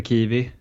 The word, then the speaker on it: Kiwi: Det var lite blandat, en del nyförvärv, bland annat eh, fina eh, namnet Klara Kempe Kiwi. – Eh, som har fått bekräftat att det uttalas som frukten.